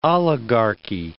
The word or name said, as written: oligarchy